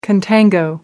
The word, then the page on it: contango: American English